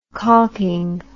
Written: caulking

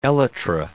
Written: elytra

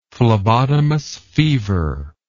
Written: phlebotomus fever

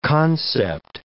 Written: concept

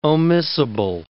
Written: omissible

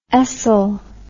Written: ESL

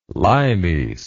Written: limes

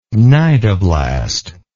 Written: cnidoblast

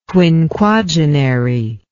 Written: quinquagenary

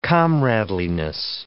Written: comradeliness

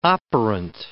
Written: operant

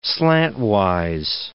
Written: slantwise

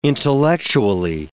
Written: intellectually

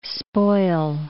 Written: spoil

